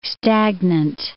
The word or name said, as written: stagnant